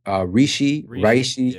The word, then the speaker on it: Reishi: Reishi, is that how you say it? – Yeah. Reishi.